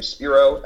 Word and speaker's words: Spiro: So it's my name, Spiro, S-P-I-R-O, in that show, spelled like it sounds.